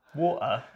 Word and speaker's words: water: So water is actually probably how you pronounce it if you're central London or from the southeast and you're getting a bit guttural. You just put an apostrophe where the T was, pretty much, and in a bit.